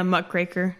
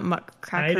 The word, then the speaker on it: muckraker: Oh, yeah, muckraker, not muckcracker.